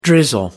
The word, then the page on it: drizzle: American English